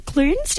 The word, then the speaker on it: Clunes: She lives in Clunes.